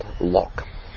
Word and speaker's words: Locke: So John Locke, L-O-K-E, unfortunately, because I've spoken with so many Germans, there's Sabina. They pronounce the E at the end, but we pronounce it in Aussie land, Locke.